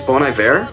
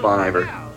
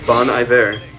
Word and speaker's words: Bon Iver: It's Bon Iver? – Yes. – Okay, everybody I know pronounces it Bon Iver. – Wrong. – Okay, Bon Iver.